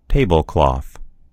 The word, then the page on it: tablecloth: American English